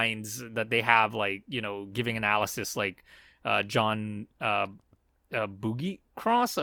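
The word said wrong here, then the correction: Buccigross: I'm not sure if I'm pronouncing his last name. – Butchagross. – Yeah.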